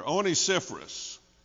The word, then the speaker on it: Onesiphorus: "The Lord give mercy unto the house of Onesiphorus." Boy, that's a name for you, isn't it? Amen. I'm glad my Bible has it broken down in syllables. Helps me pronounce it better. Onesiphorus. Notice what follows.